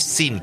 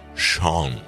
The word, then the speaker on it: Sean: no es Sin, por favor, es John, la versión escocesa de Juan. Sean, se pronuncia Sean, como Sean Connery.